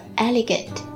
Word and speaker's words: elegant: 好，来看下一个词，叫做 elegant。 E-L-E 读作 Ellie, 然后 G-A-N-T 读作 Gant, 连起来 Elegant, 它是一个形容词，表示优雅的、雅致的 Elegant。